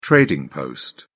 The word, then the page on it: trading post: British English